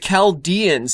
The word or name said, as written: Chaldeans